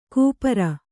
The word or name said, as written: ಕೂಪಾರ